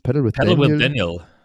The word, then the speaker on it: Paddle with Daniel: Also erstmal, wer dem allen noch folgen will, ich hatte jetzt mal geschaut, wie spricht man das denn aus? – Paddle with Daniel.